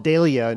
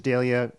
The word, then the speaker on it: Dahlia: For some reason, they call it Dahlia in Britain. My dictionary says that's what the British pronunciation is, Dahlia. Americans say Dahlia.